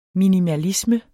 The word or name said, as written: minimalisme